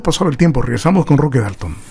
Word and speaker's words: Roque Dalton: Roque Dalton, esta es la pronunciación, maestro.